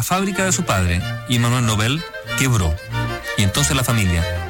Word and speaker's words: Nobel: En realidad hay que decir Nobel, porque no lleva tilde.